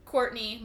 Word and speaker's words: Kourtney: I'm also on Twitter at Kourtney Mo, M-O. It's Kourtney with K.